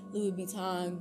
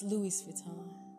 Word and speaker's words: Louis Vuitton: I'm not saying go buy Louis Vuitton, Gucci. I think I said that wrong. It's Louis Vuitton.